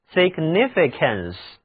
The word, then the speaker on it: significance: 这就是 significance， significance， A-N-C-E 是名词后缀，这个单词很长， C-A-N-C-E 中的字母 C 读音是 k，请跟我读 significance， significance 意思就是意义、含义、重要性。